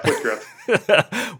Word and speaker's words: Whitgriff: It doesn't look like Whitgriff. It looks like White Griffe, which is, you know, that's a pretty good name too.